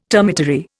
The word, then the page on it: termitary: Indian